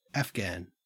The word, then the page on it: afghan: Australian English